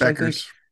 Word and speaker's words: Bueckers: How do you pronounce her last name? Bueckers? – Beckers. – It's Beckers? – Yeah. It's Beckers. Beckers.